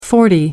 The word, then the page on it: forty: American English